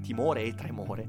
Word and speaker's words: Timore e Tremore: Leggete Timore e Trimore, eh, che, scusatemi, Timore e Tremore, eh, che è un è un mezzo scioglilingua quando lo si pronuncia velocemente. Leggete Timore e Tremore